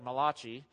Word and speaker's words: Malachi: The book of Malachi, or if you don't know how to pronounce it, it looks like Malachi. But it's Malachi.